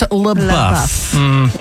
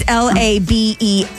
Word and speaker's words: LaBeouf: It's pronounced LaBeouf. – The way you said it was fancy. – But you left out an O in there. It's LaBeouf.